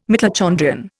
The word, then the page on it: mitochondrion: Indian